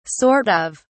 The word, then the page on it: sort of: British English